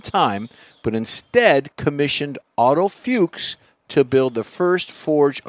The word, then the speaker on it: Fuchs: It's spelled different, but it's pronounced Fuchs. When Porsche, well, how do you spell it? – F-U-C-H-S.